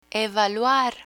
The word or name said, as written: evaluar